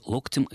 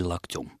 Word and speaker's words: локтем: Как правильно ставить ударение в творительном падеже единственного числа слова «локоть»? Локтем или локтем? Я предположил, что возможны оба варианта.